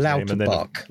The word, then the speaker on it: Lauterbach: Carl Lauterbach is his Lauterbach.